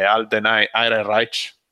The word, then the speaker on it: Alden Ehrenreich: Il fatto che siano circolati sti rumor su, su quanto fosse Pippa, l'attore principale che è Alden Ehrenreich, lo pronunciamo così? – Sì.